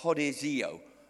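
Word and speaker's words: hodizio (ὁδηγέω): So when we look at this, we understand that the word that's used there for lead, it's a Greek word and it's pronounced hodizio.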